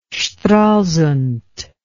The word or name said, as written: stralsund